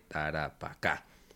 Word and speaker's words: Tarapacá: sobre todo cuando dijeron Taracapá en vez de Tarapacá.